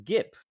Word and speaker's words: Gipp: It is actually Gip, but it's fine. – Okay, it's spelled Gip. No, you know, if I'm going to have a name that's stupid, then I expect it to be pronounced incorrectly.